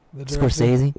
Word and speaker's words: Scorsese: Scorsese? – Yeah. The blood just squirting out. – Mm-hmm. Oh, yeah. – Scorsese. You see that in a lot of his movies. – Scorsese's movies are often quite violent.